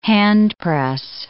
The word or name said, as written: handpress